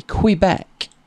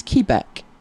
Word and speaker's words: Quebec: One was, we were taught to say Quebec. But then I got talking to a lady who was from there and she said it's Quebec. So there's a lot of different pronunciations.